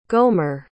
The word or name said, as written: gomer